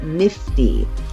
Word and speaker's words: NFT: The works are so valuable because they come with an NFT, short for non-fungible token, as you know, but pronounced nifty.